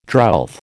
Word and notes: drouth: American English

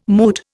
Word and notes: moot: Indian

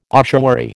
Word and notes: ossuary: American English

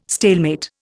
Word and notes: stalemate: Indian

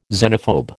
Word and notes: xenophobe: American English

